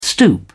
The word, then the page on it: stoop: British English